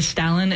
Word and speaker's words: Stalin: I've heard that pronunciation as well instead of Stalin. But pronunciations are funny things. But in any case, in England, it's very common to use the A that's just more of a typical A rather than R sound. So the Stalin is the way that I grew up pronouncing it.